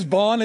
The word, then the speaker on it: Bond: My name is Bond. It's pronounced like James Bond, so that's why you said James Bond.